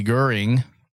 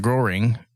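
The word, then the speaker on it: Göring: So apparently Goering is more of the German pronunciation, and Goering is more the English pronunciation.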